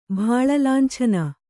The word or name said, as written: ಭಾಳಲಾಂಛನ